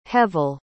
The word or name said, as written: hevel